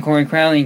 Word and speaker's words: Corey Crowley: I'm Corey Crowley, in case you're wondering how to say my name. Like many others, it's been anglicized and only Scandinavians like Svein can really pronounce it properly.